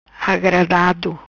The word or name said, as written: Agradado